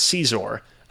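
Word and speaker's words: Xizor: Who the fuck is Scissor in that? – So, for context, I believe it is pronounced Caesar.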